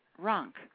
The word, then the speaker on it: Rank: The man that I was married to, my second marriage, that was his name, and he always pronounced it Runk, but it's been easier for everybody to spell by pronouncing it Rank, so I pronounce it Rank.